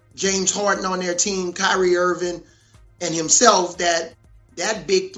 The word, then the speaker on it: Kyrie: No matter what he says publicly about, you know, Kyrie needs to do what's best for him. – I'm sorry, I said his name wrong. Kyrie.